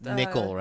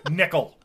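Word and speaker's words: Nicol: Nicole, right? – Nicole? Yeah, whatever. – Not Nicole. Nicole. – Nicole. – I'm sorry. Nickel.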